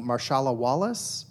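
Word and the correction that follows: Marisha Wallace: Marisha Wallace went on as Kasim.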